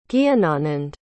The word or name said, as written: Gyananand